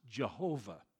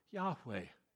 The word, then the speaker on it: Yahweh: Number one, there is no J or V in the Hebrew alphabet. It is impossible to pronounce God's name Jehovah. We do because of the influence of the German language upon the English culture. That's the only reason we pronounce it there. There's no J or V in it.